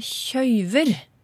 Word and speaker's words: kjøve: Og det er en uttalevariant av å, å kjøve. Og uttale med diftong det finner du flere plasser på Østlandet, du finner det på Innherred i Nord-Trøndelag, og du finner det oppe i Nord-Norge.